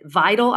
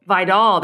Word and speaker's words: Vidal: Vital. I want. I hope I'm saying that right. The running back because dude is a monster. – Kimani Vidal. – Vidal.